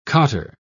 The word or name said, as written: cotter